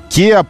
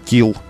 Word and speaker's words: Kia: Kia папа называет Kill, Владимир Владимирович, и Maybach.